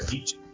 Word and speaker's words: Nietzsche: Se pronuncia Nietzsche. Nietzsche. No, no, no, no, no, no es Nietzsche, es Nietzsche. – Ah, Nietzsche. – Exactamente.